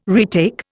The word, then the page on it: retake: Indian